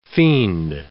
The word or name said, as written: fiend